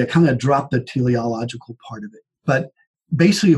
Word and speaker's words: teleological: Maybe I'm not even pronouncing it right. Maybe it's teleological. – Yeah. – It's teleological.